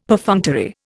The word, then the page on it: Perfunctory: Indian